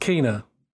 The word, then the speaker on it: Kena: I think it's pronounced Kena, actually, because in the last trailer, in the future, what is it, future game show or whatever, something like that, I think the person said Kena on the trailer.